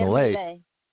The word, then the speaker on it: Milei: Buenos Aires Herald said, Argentina 2023 elections, Milea, you pronounce that right? Milea, M-I-L-E-I. – Javier Milea.